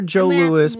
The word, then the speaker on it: Joe Louis: I prefer Joe Louis, pronounced Joe Louis.